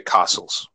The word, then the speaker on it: castles: Lot of, you don't you don't need to pronounce the T. You can just say castles.